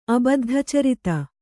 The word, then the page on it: ಅಬದ್ಧಚರಿತ: abaddhacarita